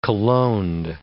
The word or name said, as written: cologned